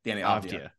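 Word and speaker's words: Deni Avdija: And then basically your other is Poku for, I'm going to butcher this guy's name, I'm just keeping with the style here, Denny of Avija? – Avdija. Danny Avdija.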